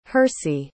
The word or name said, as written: hirsi